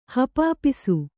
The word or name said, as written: ಹಪಾಪಿಸು